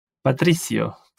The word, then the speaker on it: Patricio: So Patricio or Patricio or what? – Or if you ask me, I would suggest Patricio.